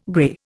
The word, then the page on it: grey: Indian